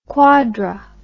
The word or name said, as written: quadri